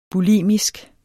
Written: bulimisk

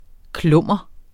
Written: klummer

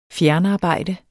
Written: fjernarbejde

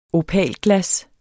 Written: opalglas

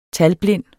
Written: talblind

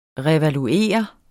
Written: revaluere

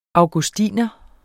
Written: augustiner

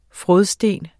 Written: frådsten

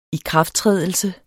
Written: ikrafttrædelse

